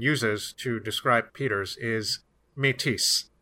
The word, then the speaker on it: Métis: We call them the Métis mostly in English. We don't pronounce the S in English so much,